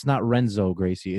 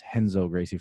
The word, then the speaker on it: Renzo Gracie: And then whenever you see a R, like it's not Renzo Gracie, it's Henzo Gracie, for example, or something like that.